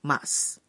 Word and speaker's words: ます: শুনে শুনে বলতে হবে জাপানি ক্রিয়ারূপের মাস অংশটি হিরাগানা লিপিতে লেখা হয় মা এবং সু অক্ষর দুটি পরপর ব্যবহার করে এই সু অক্ষরের উকার অনুচ্চারিত থাকে তাই ক্রিয়ারূপের এই অংশটির উচ্চারণ হবে মাস 勉強します。日本語を勉強します。大学で日本語を勉強します。